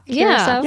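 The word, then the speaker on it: Curaçao: At this point, the ship was just about to dock in Is it Curacao? Is that how you pronounce? – Yeah. – Curacao? – Yeah. Okay. Yeah.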